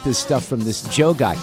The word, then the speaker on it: Djo: So there's a bumper music piece that I played, and one of my favorite songs right now is by an artist that goes by the name of Joe, but it's spelled D-J-O, all right? – Yeah. – So but it's Joe, and this song is called Basic Being Basic.